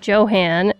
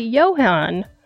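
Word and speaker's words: Johann: I called him Johan, and it's actually Johan.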